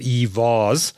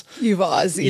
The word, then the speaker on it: EVaaS: EV how do you pronounce it? EVaaS. – EVaaS, electric vehicle as a service.